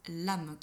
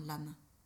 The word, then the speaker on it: LAM: La syllabe qui est rattachée, c'est la syllabe l'âme », qui s'écrit L-A-M, mais qui se prononce comme si tu mettais un E à la fin, l'âme ».